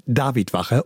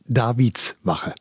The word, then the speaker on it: Davidwache: Es heißt Davidwache und nicht Davidswache.